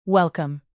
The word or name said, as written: welcome